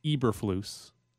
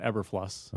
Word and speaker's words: Eberflus: According to Q, it is pronounced Eberflus. – Eberflus. – Not Eberfluss.